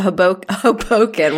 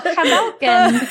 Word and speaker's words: Hoboken: The more you know as a Hoboken. Wow. I pronounced it like I'm in hello. Oh, hello. – Hoboken. – Oh, hello. – Oh, Hoboken.